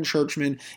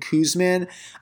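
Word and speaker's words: Czuczman: Yes, that is actually how you pronounce his last name. It is Kevin Churchman, even though it looks like it's Kevin Kuzman.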